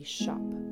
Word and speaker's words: scop: I'm taking my Celtic harp and I want to act as a shop. It's an old English word spelled S-C-O-P, but pronounced kind of like you're a little British and you are talking about a retail shop, right?